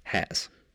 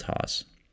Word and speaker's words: Haas: Because it's not pronounced Haas. – No, it's pronounced Haas. – Yeah. So yeah, get that right.